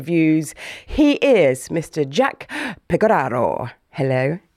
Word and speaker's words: Pecoraro: She got it Pecoraro? – Bang on. – Yeah. Pecoraro. – It's supposed to be Pecoraro in Italian.